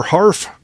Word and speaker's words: Harf: Harf rhymes with barf.